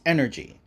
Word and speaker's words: NRG: Creator's Corner, NRG Comics, NRG, but pronounced energy.